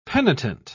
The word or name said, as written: penitent